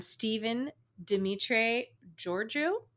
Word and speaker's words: Stephen Dimitri Georgiou: He was actually born Stephen Dimitri Georgiou.